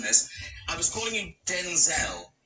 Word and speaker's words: Denzel: So his name is Denzel. – No, Denzel. – Denzel. – It's Denzel, and his mama called him Denzel.